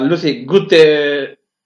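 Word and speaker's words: Lucy Gutteridge: Luego tenemos a Lucy Guterridge, perdón por mi pronunciación. – Lo hace perfecto, hombre.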